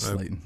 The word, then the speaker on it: Slayton: And then Liberty Baseball with Slayton. I think that says Slayton. It's spelled incorrectly. – Yes, that says Slayton, yeah. It's like Darius Slayton.